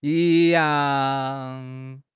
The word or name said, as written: yi1 yang4